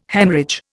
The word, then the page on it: Haemorrhage: Indian